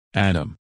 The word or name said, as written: Adam